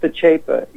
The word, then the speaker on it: Pacepa: Is this disinformation, the author, Pacepa, is that the name of the author, Pacepa? – Yeah, it's pronounced Pacepa, yeah. – Pacepa, okay, well, I got it. – I-O-N, his first name is I-O-N.